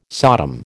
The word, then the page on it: Sodom: American English